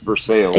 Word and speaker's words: Versailles: I don't, because when I got my assignment, I said Versailles. Where's that? – Yeah, and that's Captain J.D. Byron was like, "No, it's pronounced Versailles."